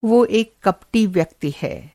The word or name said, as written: कपटी